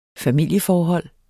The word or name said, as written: familieforhold